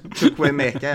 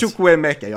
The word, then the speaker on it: Chukwuemeka: Shimovuka, egna produkten också. – Ja, just det. Du menar Chukwuemeka, ja, som vi fick in från Aston Villa. – Där satt uttalet.